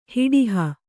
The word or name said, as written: ಹಡುಹು